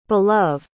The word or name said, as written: belove